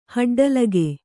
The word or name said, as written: ಹಡ್ಡಲಿಗೆ